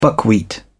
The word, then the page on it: buckwheat: British English